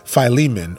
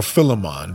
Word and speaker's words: Philemon: Onesimus, we're going to read later on in the book of Philemon or Philemon, depending on how you say it.